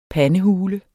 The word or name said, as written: pandehule